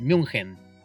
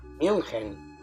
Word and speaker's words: München: Lo que pasa es que en alemán es München. – München.